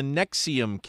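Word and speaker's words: NXIVM: There's an update on the Nexium case. That's spelled N-X-I-V-M. I'm not sure how you get Nexium out of that, but that's what it is.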